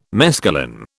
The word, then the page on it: mescaline: British English